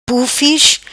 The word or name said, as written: Pufis